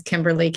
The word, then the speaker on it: Kimberlee: And my name is Kimberly, K-I-M-B-E-R-L-E-E.